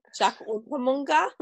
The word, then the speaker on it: Unterweger: How do you say, Unterweger. – Jack Unterweger.